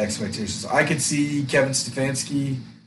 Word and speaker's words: Stefanski: Kevin Stefanski.